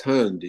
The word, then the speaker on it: Hund: It's Hund, yes.